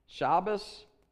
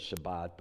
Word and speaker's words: Shabbos: Shabbos, I know everybody says Shabbat, but I grew up with the Ashkenazic pronunciations, and Shabbat is the Sephardic pronunciation. That's the Spanish-speaking Jewish people, and they don't know what they're doing, but anyhow, so.